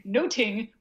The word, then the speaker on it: nothing: There's like 3 puns in there, and we maybe only get 2 of them now because we don't pronounce nothing noting, which is apparently the way they used to pronounce it, something much more like a noting.